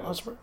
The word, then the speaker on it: Ospreay: So, I got his name right? – Yeah. – Ospreay? – Yeah, that's good.